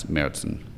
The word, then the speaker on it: Märzen: There should be two dots above the A, which is an umlaut, and that's Märzen. Like a nightmare. – Okay, like a Märzen.